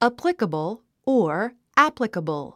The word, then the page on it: applicable: ǽplikəbl; アプリカボォ